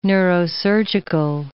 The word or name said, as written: neurosurgical